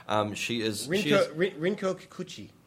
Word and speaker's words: Rinko Kikuchi: Rinko Kikuchi.